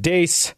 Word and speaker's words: Deace: And I am excited to bring on Steve Dace, host of Steve Dace Show on Blaze TV.